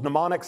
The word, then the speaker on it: mnemonic: Mnemonic starts with a silent M, mnemonic.